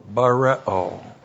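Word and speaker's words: bareo (βαρέω): B-A-R-E, long O, bareo.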